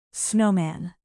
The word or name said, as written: snowman